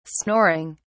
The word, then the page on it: snoring: British English